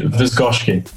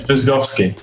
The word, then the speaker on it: Wyszkowski: It's Louis Wyszkowski. – Wyszkowski. – Yeah, it's Wyszkowski. Yeah, it's a Polish name.